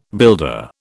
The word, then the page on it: builder: British English